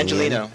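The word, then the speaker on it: Angeleno: How do you say that, Los Angeles? – Angelino. Angelino. Angelino.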